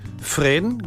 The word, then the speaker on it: Fresne: Die Esche heißt im Französischen Fresne, geschrieben Fresne.